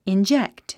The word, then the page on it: inject: indʒékt; インジェクト